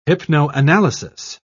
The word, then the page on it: hypnoanalysis: British English